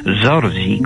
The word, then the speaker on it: Zorzi: En veneciano popular decían también Zorzi, con Z pronunciada así, algo así como el gran Jorge, digamos.